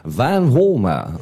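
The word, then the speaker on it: Vanwarmer: Dit was natuurlijk Randy van Walmer. Van Walmer, I believe. That's how you pronounce his surname.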